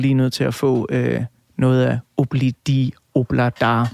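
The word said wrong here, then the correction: Ob-La-Di, Ob-La-Da: Mm. Udtaler jeg det overhovedet rigtigt? – Obladi Oblada. – Obladi Oblada. Ja.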